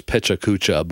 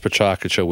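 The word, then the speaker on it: Pecha Kucha: It looks like it says Pechacucha, but it's pronounced Pachacucha, which is Japanese for chit-chat.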